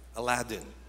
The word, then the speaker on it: Aladdin: So we decided maybe let's just watch Aladdin or Aladdin. That's how it's pronounced.